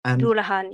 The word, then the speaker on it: Dullahan: And can you tell us a little about the nature of you mentioned it very briefly earlier, and forgive me, I'm not sure if I'm pronouncing it correctly, but the Dullahan Doulahan, yes.